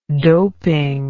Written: doping

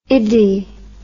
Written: iddy

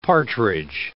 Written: partridge

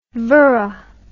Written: vora

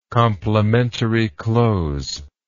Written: complimentary close

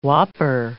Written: whopper

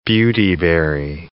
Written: beautyberry

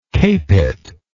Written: Capet

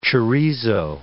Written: chorizo

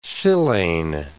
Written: silane

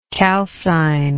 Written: calcinatory

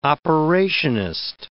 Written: operationist

